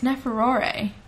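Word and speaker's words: Neferure: which was Nefefare. It's Neferore. That's why I'm saying it wrong.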